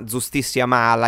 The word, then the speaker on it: Giustissima Mala: Gigi, allora tu sei qui oggi per raccontarci questo nuovo brano che si chiama Giustissima Mala. Io spero che tu mi passi la pronuncia, spero che sia corretta. – No, giustissimo.